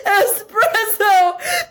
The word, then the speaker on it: espresso: Like it's pronounced espresso.